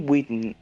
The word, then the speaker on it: Whitman: Tenemos a Dane Whitnam. Eh, ¿Cómo se dice? Dane Whitnam. – ¿Cómo es el, nombre? – Dane. Dane Whitman. Dane Whitman. – Claro. Whitman, ahí está Whitman.